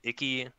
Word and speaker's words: Iki: And there's also, in the normal story mode, there's Iki, I think is how you pronounce it, IKI.